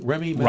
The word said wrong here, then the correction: Rami Malek: Rami Malek. – Rami yeah.